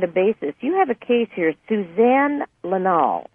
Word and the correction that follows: Lyall: I may not be pronouncing her last name correctly. It's L Y. – Oh, Lyle, Suzanne Lyle, yeah. – Ann Lyle, okay. Spelled L Y A L L.